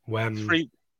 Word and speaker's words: Wembley: Wembley. – There you go. Three syllables.